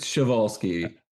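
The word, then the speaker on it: Przewalski: Chvalsky. – Okay. – I think. – Is it Chvalsky horses? – It is Chvalsky.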